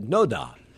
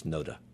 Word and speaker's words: NoDa: Let me begin with the pronunciation of NoDa, because yesterday when we were talking about doing this show, I said NoDa, and then I got an email from a very nice listener who said, please tell Mike it's NoDa.